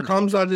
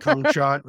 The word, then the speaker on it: Khamzat: Whenever you say his name, it sounds like I should bleep it out. Like, it sounds, you say it in a sexual way. – Do I say it like that? – You say like Khamzat. – Or I say like cum shot or something. – Yeah. I think if it sounds like you're calling him cum slut, that's what I don't like.